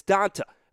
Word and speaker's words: Dont'a: It's Dont'a. Okay, there is no E in there. It's Dont'a Hightower, right?